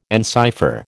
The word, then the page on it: encipher: American English